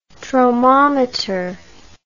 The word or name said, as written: tromometer